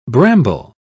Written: bramble

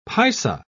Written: paisa

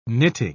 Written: knitting

